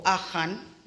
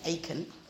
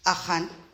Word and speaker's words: Achan: For Achan, that's how you actually say his name, not Achan, Achan,